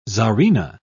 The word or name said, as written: czarina